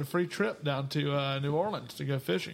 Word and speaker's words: New Orleans: That's the appropriate pronunciation is New Orleans.